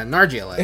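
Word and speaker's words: Nargeolet: Nargalette. – As simple as that. – Yeah, Nargalette.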